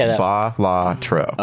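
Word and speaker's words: Balatro: That's why I think I'm right. I'm pretty sure it's BAH-lah-troh.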